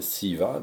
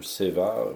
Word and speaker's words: SEVA: Euh, on pourra arriver sur des exemples un petit peu plus tard, mais donc c'est cette, euh, cette dimension qui, qui se nomme SIVA, donc ou SEVA, enfin peu importe là d'où vous venez, mais en gros, c'est, ça s'écrit S-E-V-A.